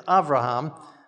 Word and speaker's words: Avraham: And if we're going to pronounce Avimelech as Avimelech, maybe we should have been pronouncing Abraham as Avraham. But we've been doing Abraham all along, so I think we'll keep with that for this week.